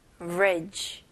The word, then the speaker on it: average: 그래서 E를 그냥 생략을 해서 아첫 음절, 그 다음에 그냥 두 번째 음절은 ridge, ridge. 오케이, 다리 할 때, bridge 이잖아요. 여기서는 그냥 V 발음으로 average, average. 오케이, 여기서도 강세가 첫 음절에 들어갑니다.